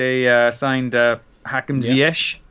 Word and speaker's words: Hakim Ziyech: One of the first players they signed, Hakim Ziyech.